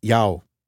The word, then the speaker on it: Yao: It's pronounced Yao.